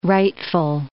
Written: rightful

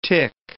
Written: tick

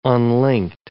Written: unlinked

